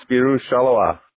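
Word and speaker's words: Spirou Charleroi: He's currently in America, so not quite, but he is the head coach over in Belgium for Spiro Charleroi. Did I say that right? – That's correct. Spiro Charleroi.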